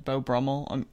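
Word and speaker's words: Beau Brummell: Sometimes they refer to him, and I think I'm pronouncing this right, but I've only ever written it Beau Brummel. I mean, I know that's sort of like the precursor to a dandy or a fop. – Right. Actually, that's the way we pronounced it in other episodes, past episodes. So we're going to go with that.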